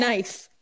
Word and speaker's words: knife: Knife. Why is there a K? – Pterodactyl.